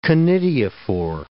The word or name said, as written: conidiophore